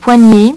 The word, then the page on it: poignet: French